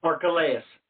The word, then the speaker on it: Archelaus: Archelaus.